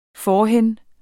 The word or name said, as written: forhen